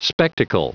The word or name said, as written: spectacle